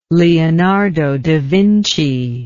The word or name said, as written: leonardo da vinci